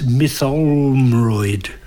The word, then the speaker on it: Mytholmroyd: And it's in the West Yorkshire village of, I'm trying to remember how to pronounce it, it's Mytholmroyd.